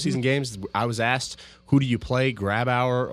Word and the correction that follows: Grubauer: Grubauer or Holtby.